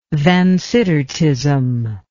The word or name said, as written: Vansittartism